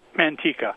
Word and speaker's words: Manteca: Manteca.